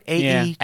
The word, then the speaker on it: Aegis: Aegis. Aegis. – Why are you doing this? – I think it's a hard G. – I don't think it is. I feel like it's a soft G.